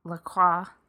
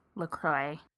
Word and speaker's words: LaCroix: I mean, so it's French, because in French you don't say the X either, but still not French. So it's ambiguous. – The silent X in LaCroix, LaCroix.